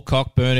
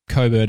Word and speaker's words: Cockburn: or Cockburn if you want to read it, but Coburn is how you say it.